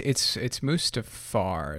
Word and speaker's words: Mustafar: Which it's Mustafar.